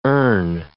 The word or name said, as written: urn